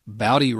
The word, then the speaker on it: Bowdy: Bowdy rhymes with howdy. – You mean how to pronounce his name? – That's what I said. Um, Brian Bowdy.